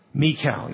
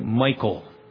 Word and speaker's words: Michal: Third deliverance, verses 11 to 17, by the hand of his wife, Michal. You'll hear people say Michael. Jars my ears because it's not Michael, not pronounced Michael in the Hebrew,